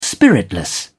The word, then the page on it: spiritless: British English